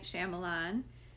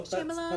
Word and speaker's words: Shyamalan: Night Shyamalan. Do I say that right? – I think so. Shyamalan? That's how I say Shyamalan.